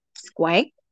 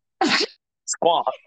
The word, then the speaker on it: Squonk: Q-U-O-N-K. – Okay. So I spelled S-K-W-A-N-K, okay? – Squank? – Squonk.